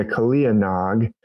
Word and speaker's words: Kaliya Nag: Is Krishna defeating the Kaliya Nag? It's usually pronounced without the A on the end.